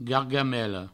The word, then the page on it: gargamela: gargamello